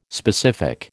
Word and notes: specific: American English

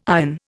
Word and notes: iron: Indian

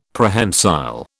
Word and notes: Prehensile: British English

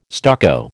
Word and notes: stucco: American English

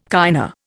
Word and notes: kinda: Indian

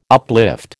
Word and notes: uplift: American English

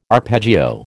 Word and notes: arpeggio: American English

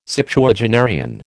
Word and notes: Septuagenarian: American English

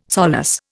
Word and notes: solace: Indian

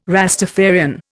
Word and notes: Rastafarian: Indian

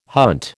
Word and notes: hunt: American English

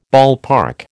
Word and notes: ballpark: American English

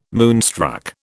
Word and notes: moonstruck: British English

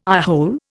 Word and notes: eyehole: Indian

